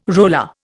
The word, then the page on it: roller: Indian